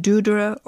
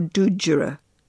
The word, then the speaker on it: dúdra: The word dúdra is a trisyllabic word, pronounced dúdra or dúdjura.